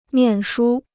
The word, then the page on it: 念书: niàn shū